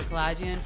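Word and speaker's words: Kalajian: Yeah, I just go by my name, Andrea Kalagian, both on Instagram and Facebook.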